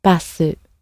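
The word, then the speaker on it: バス: So obviously it comes from the English originally and has had the extra vowel sound added to the end and a more of a softer a sound at the beginning, basu.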